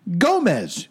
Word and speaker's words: Gomez: Now, okay, I had to look up her name because it's spelled X-O-C-H-I-T-L. And I absolutely was not going to attempt that. – X-O-C-H-I-T-L. – And her last name, it looks like Gomez, right? G-O-M-E-Z. – It's not that. – Here's... No, it is. And here's how... – Oh, it is Gomez.